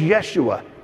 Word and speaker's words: Yeshua: His name doesn't start with a J, it starts with a Y, because he was a Hebrew kid. His name was Yeshua, which is Hebrew for Joshua. It's how you say it.